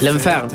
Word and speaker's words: L'Enfer: L'Enfer, no sé si estoy bien con la pronunciación, es el infierno de su último disco. – Sonó bien. – Sí. Sonó bárbaro.